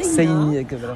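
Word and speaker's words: Seina: En fait, ça s'écrit un peu comme la Seine, mais... – Voilà, avec un A à la fin, Sena, on prononce le I aussi. – Comme si on avait un tréma au-dessus.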